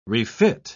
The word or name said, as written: refit